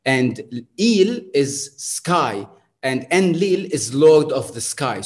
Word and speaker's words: Enlil: And notice that I'm like, the English pronunciation of Enki and Enlil, right? Like anytime you would hear it, it would be Enlil. However, it's in fact, there's an emphasis on the il, which becomes Enlil.